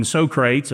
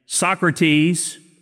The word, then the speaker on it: Socrates: Now, you've probably heard these names, Aristotle, Socrates, or I used to call him Socrates, and I found out that was not how you pronounce the name. Socrates, Plato, Aristotle, these guys lived hundreds of years before Jesus.